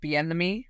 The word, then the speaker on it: Bieniemy: I actually didn't realize that, forgive me if I say his name wrong, Eric Bieniemy, say his second name.